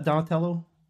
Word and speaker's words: Donatello: Did I say that, Donatello? – Yes. You did say that right.